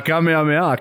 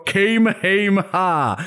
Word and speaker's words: Kamehameha: La Kamehameha, che giustamente gli streamer americani non hanno mai sentito pronunciare, quindi la chiamano Kamehameha.